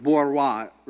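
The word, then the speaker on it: bourgeois: There was the Borois, spelled really weird, children. It looks like Borgiosis or something, you know, but it's French. The French don't pronounce half their things, and so it's Borois, right?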